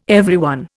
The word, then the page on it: everyone: Indian